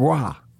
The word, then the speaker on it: Roy: Tenderloins for Patrick Roy, pronounced Patrick Waugh.